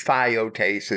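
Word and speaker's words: theotes (θειότης): The Greek word theos and the related words theotes and theotes, two slightly different spellings, so they have two slightly different proper pronunciations. Theotes is T-H-E-I-O-T-E-S, and theotes is T-H-E-O-T-E-S.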